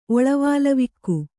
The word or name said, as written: ಒಳವಾಲವಿಕ್ಕು